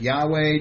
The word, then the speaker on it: Yahweh: Okay, some would pronounce it Jehovah. Others would pronounce it Yahweh, depending on your whether it was German or Aramaic or what have you.